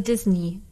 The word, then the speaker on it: Disney: Und da gab es ein Forum und da hat nämlich jemand geschrieben, yes, Disney with the ending to rhyme with see me be. – Also Disney.